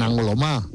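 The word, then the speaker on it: Angoulême: Y aparte, hemos estado, aparte de enfermos, yo he estado en Angulema. Angulema es como se pronuncia. – Sí. – Angulema es como se escribe.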